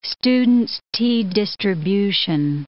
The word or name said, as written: student's t distribution